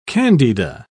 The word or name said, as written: candida